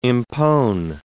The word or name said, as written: impone